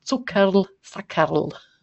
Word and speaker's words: Zuckerl-Sackerl: Alle Zuhörerinnen aus Österreich mögen mir verzeihen, ein Zuckerl-Sackerl.